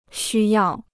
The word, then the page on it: 需要: xū yào